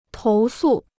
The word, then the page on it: 投诉: tóu sù